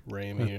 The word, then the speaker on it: Rami: Is it Rami? Remy? – Rami.